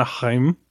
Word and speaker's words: Haim: The brand new film from writer-director Paul Thomas Anderson, and this movie stars Alana Haim. Am I saying that right? I assume I'm saying that right. – Haim. – Alana Haim.